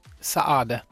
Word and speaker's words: Saade: Saade.